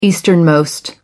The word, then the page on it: easternmost: American English